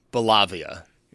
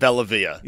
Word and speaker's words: Bellavia: And nobody said Bellavia. They all said Bellavia. – Yeah, it's good because I think the natural pronunciation would be the wrong one.